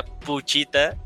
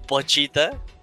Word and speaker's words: Pochita: pero te, te das cuenta que de ahí también nuestro pensamiento inicial, y lo comentamos en el primer episodio, que pensábamos que se pronuncia puchita y no pochita.